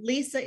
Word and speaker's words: Lysa: lysa is spelled a little bit differently it's l y s a